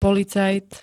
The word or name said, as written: policajt